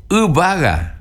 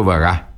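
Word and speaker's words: yvága: Con la U de agua al principio, con la B corta, no se olvide, y acento en la A. Porque no digo vaga, ubaga.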